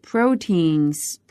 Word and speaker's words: proteins: 好的那我们把这个句型还原的话 and lean proteins are important for post-workout 在这里 lean proteins lean L-E-A-N 它的意思是瘦的精瘦的 lean proteins 那么 protein 呢是蛋白质的意思 proteins proteins 注意一下它的重读音节的地方